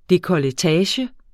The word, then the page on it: dekolletage: dekʌləˈtæːɕə; ˈdekʌləˌtæːɕə